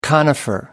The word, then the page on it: conifer: American English